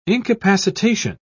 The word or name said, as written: incapacitation